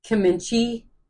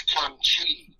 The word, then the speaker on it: kimchi: She talks about this stuff called kimchi. It's spelled K. It's spelled K. I'm probably saying it totally wrong. – It's kimchi. – Kimchi, yeah. It's spelled K-I-M.